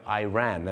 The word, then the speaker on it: Iran: แต่ชาวอเมริกาเยอะมากครับจะออกเสียงว่า Iraq กับ Iran นั่น